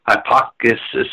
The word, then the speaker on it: Hipparchus: Hipparchus, H-I-P-P-A-R-C-H-U-S, made an astounding discovery.